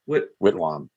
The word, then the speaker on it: Whitwam: Charles Whitwam, is that how I pronounce it? – Whitwam. – Yep.